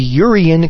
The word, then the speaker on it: EURion: A number of recent banknote designs contain a pattern known as the Urian constellation. This is spelled E-U-R-I-O-N, by the way, constellation.